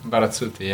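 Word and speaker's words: Barazzutti: Corrado Berizzuti? How do you say that? – Barazzuti, yeah.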